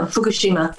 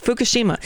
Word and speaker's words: Fukushima: Do you pronounce it Fukushima or Fukushima? – No, Fukushima. – Fukushima. Okay.